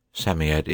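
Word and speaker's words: Psammead: Samiad is pronounced Samiad. It was old,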